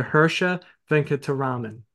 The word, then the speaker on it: Hirsha Venkataraman: Absolutely ecstatic to have him on the podcast today, Mr. Hirsha Venkataraman.